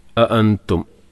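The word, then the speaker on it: أأنتم: അഅൻതും, അഅൻതും ഹംസ പറഞ്ഞല്ലോ, ശ്രദ്ധിച്ച് ഉച്ചരിക്കണം. മലയാളത്തിൽ നമ്മൾ ആ പറയുന്ന ഉച്ചാരണമല്ല, അ കുറച്ചും കൂടി ഹൽക്കുന്നാണ് ശബ്ദം വരേണ്ടത്. അഅൻതും, അഅൻതും അഷദ്ദു ഖൽഖൻ അമിസ് തൻവിന് ശേഷം ഹംസ, പിന്നെ സമാഉ മദ്ദിന് ശേഷം ഹംസ.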